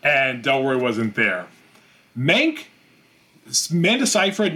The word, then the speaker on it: Seyfried: Amanda Seyfried, are we saying that right? Seyfried.